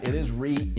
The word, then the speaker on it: REIT: Well, first off, you got it right. It is REIT. You know, rhymes with beet, rhymes with sweet, all that.